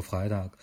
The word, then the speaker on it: Freitag: 嗯、我们这期就按呃就按德语或者瑞士德语的发音发音来说，这个牌子叫 Freitag。